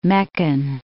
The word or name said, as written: Meccan